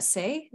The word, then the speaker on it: C'est: Their name is Bonnie from the, I don't speak French, so help me, Cheyenne, but it's the C'est. Is that Say? Is that how you pronounce that? – Yeah.